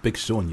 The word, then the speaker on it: Big Sean: But first, let's have a little bit of, I don't know who this is, Big Sean, am I saying that right? – Big Sean, yeah.